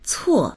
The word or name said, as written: cuo4